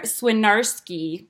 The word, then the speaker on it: Swinarski: Another interview, this time with a fellow podcaster, Claire Swinarski.